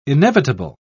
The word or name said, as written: inevitable